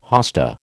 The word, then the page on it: hosta: American English